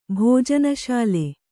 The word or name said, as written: ಭೋಜನಶಾಲೆ